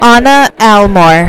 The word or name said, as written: Anna Almore